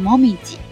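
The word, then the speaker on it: 紅葉: 当然它也可以读成猫咪吉，猫咪吉的时候表示的是枫叶哈。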